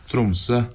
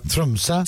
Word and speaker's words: Tromsø: Trumse. – Trumse.